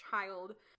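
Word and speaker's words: Childe: Before anyone says anything, if they're not familiar with the series, his name is Child, but with an E. I really wish it was pronounced a different way, but unfortunately, it's pronounced Child.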